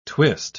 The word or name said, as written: twist